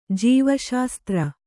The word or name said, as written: ಜೀವಶಾಸ್ತ್ರ